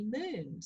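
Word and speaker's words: Moone: And anyway, so the next day, Jackie, who is a dear friend still to this day, just started calling me Moon. So, you know, for the moonshine. So I have been Moon ever since then.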